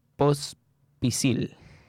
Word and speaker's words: Pospisil: post Pospisil. ¿Lo dije bien? – Sí, perfecto. Sí, post Así es.